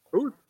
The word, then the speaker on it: Hooth: Welcome to the pre-MLB draft show, the first half recap for Motor City Metrics with myself, John Uper, and a special guest, Trevor Hoth, I believe that's how you say it. – Hoth. – Hoth. Sorry, I'm terrible with pronunciation.